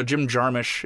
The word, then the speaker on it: Jim Jarmusch: But then you see people that you do care about and you're like, whoa, I've told you this story, but I saw Jim Jarmusch.